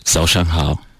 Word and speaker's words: Zao shang hao: Zao shang hao. – Sorry? – Zao shang hao. – Zao shang hao. Did I pronounce it right? – Yeah, you try your best.